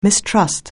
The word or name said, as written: mistrust